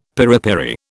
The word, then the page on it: piripiri: British English